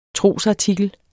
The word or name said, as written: trosartikel